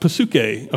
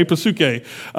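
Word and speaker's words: psuche (ψυχή): The Greek word for soul is pronounced, it's funny because in English we silence the P, but in Greek the P is pronounced. And so it's pronounced psuche, okay, psuche.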